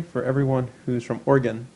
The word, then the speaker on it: Oregon: I should actually say Oregon.